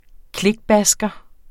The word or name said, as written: klikbasker